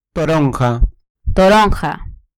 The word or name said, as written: toronja